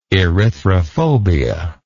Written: erythrophobia

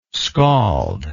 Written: scalled

